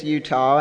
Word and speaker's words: Jutta: Which is kind of unique in itself, but you really spell it J-U-T-T-A. It looks like Jutta, it's German, and in Germany it's real common, and here it is not.